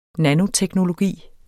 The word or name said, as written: nanoteknologi